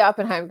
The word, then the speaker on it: Oppenheim: No, so it's the Oppenheim group, but it's okay. – How do you say it again? – Oppenheim. – Oppenheim. Sorry, guys. – It's okay. Everyone says, no, it's okay. People say Oppenheimer too.